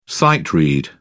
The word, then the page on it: sight-read: British English